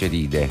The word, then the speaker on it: Facceride: Eh, sì, sì, l'accento è sbagliato, che si legge facce facceride.